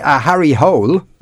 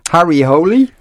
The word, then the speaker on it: Harry Hole: and someone's texting to say Harry Hole is actually pronounced holy. So it's Harry Holy.